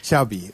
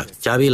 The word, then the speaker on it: Xavi: Xavi. – Okay. Yeah, Xavi like chocolate.